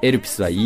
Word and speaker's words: Elpis: The、と書いて The、そしてエルピスは ELPIS でエルピスです。どういう意味かというと、パンドラの箱ってこうギリシャ神話であると思うんですが、あの箱の最後に残ったものがエルピスって言われてるんですよね。で、そのエルピスっていうのは英語で言うとホープっていう意味で、